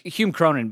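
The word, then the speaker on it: Hume Cronyn: Is it Cronin? It's just spelled a different way. – Yeah. – Cronin is a family name. So yeah, it's Hume Cronin, but it's spelled C-O-R-Y-N. N-I-N or whatever.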